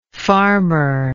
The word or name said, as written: farmerlike